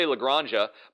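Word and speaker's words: La Granja: I keep wanting to say Lagranja, but apparently the J is an H, so it's Lagranja, which is, you know, anyway.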